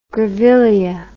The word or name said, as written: grevillea